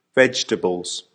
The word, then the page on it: vegetables: American English